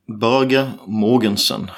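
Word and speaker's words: Børge Mogensen: Börge Mogensen. Ja, vi säger det. Börje Mogensen. – Ja, det är Börje Mogensen. Som vi säger på småländska.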